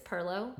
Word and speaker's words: pilau: Yeah, because it's spelled P-I-L-A-U, but it's perlo.